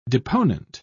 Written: deponent

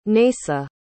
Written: naysa

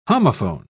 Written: homophone

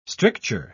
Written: stricture

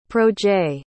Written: projet

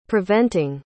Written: preventing

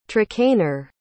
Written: trakehner

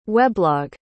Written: weblog

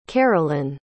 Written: carolin

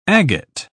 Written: agate

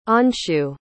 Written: anshu

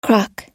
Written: croc

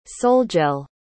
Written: solgel

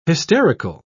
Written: hysterical

